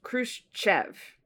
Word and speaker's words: Khrushchev: Well, it's Khrushchev. – Khrushchev. It's just sha, sha, sha, sha, sha.